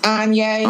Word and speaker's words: Anyea: No, my name is pronounced Anyea.